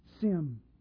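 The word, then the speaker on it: sim (שים): The word in Hebrew is S-I-Y-M, and it's pronounced sim.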